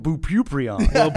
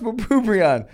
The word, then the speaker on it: bupropion: He was on Bupropion.